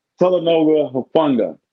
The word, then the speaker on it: Talanoa Hufanga: So I don't know how to say it. What's his name? – Talanoa Hufanga. – Yeah, there we go.